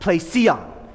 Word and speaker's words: plesion: The Greek word that Luke uses there is, I'm going to say it wrong, I always mess it up, plesion, P-L-E-S-I-O-N.